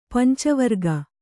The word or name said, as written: ಪಂಚವರ್ಗ